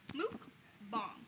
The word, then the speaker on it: mukbang: B-O-N-G. – Okay. – Mukbang. – So mukbang. – Mook. – Mook. – So it's like book with an M. – Yes. – Mook. – Mukbang.